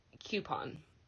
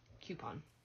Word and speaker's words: coupon: Coupon. Coupon, not coupon.